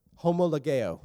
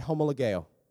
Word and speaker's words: homologeo: and here's how it's pronounced, homologeo. So look at the person next to you, say homologeo.